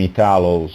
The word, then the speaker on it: Italo: And in English, I'm Italo. So it changes according to language.